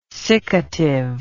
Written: siccative

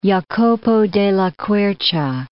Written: Jacopo della Quercia

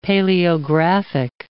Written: paleographic

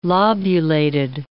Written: lobulated

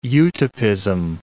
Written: utopism

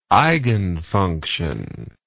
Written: eigenfunction

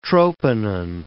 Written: troponin

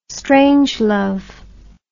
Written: strangelove

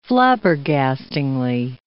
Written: flabbergastingly

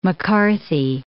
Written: M'Carthy

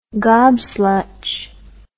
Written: gobslutch

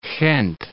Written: Gent

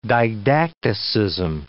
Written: didacticism

